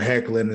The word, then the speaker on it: Hoechlin: You used Tyler Hoechlin as Superman. Did I say that right? – Yes.